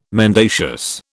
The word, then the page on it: mendacious: British English